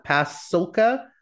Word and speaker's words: Pasulka: And it's you and Diana Pasulka. What's that? – Pasulka. – Pasulka. Yeah. Okay. I was worried that I was getting her name wrong, with guest Whitley Strieber.